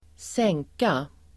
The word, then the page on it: sänka: [²s'eng:ka]